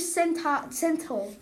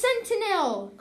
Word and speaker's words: sentinel: Threatened the British Sentinel. – We went over this. It's Sentinel.